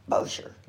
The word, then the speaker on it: Bossier: Did I say it right? Bossier City? – Bossier. – Bossier City, Louisiana.